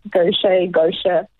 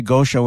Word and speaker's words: Gaucher: Gaucher. – Yeah, no. I was going to say Gaucher when I started the show, and Tati was no, Orbs. It's Gaucher.